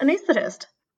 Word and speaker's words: anaesthetist: No, that was correct. Anaesthetist.